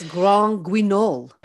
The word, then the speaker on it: Grand Guignol: Have I said that right? – I think it needs to be more French, sort of Grand Guignol. – Okay. Yeah, I think it's Grand. – There you go. It's Grand Guignol.